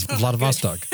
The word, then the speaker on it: Vladivostok: It's Vladivostok.